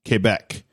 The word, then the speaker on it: Quebec: From Port Alberni to Pointe Claire, Quebec, or Pointe Claire, Quebec. – Wow. You even said Quebec. – Yeah, well, that's how you say it.